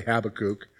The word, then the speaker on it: Habakkuk: Sometimes you will hear people pronounce it as Habakkuk, okay? Uh, most people just say Habakkuk, although occasionally you might hear somebody say Habakkuk.